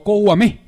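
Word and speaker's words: Kouamé: O Kouame. Tiene acento en la E.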